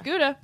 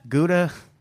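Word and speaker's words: Ghouta: Is that pronounced Gouda? – Yep. – I think it's Gouda. – It's Gouda. – Gouda.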